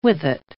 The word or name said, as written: with-it